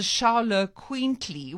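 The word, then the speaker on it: Xoloitzcuintli: the indigenous Mexican breeds like the Chihuahua and the Charlo Quintly, which is, I went and worked out how to pronounce that because it's certainly not the way you spell it.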